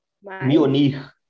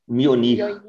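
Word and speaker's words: Mjölnir: Mjolnir. – Mionir.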